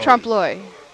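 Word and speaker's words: trompe l'oeil: How do you pronounce that? T-R-O-M-P-E-L-O-E. – Trump Loy.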